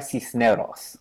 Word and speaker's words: Cisneros: But I said her name wrong. How should I say it? – I would say Sandra Cisneros. – Sandra, C- Sandra, see, I'm bad at the R's too.